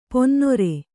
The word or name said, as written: ಪನಿನೀರು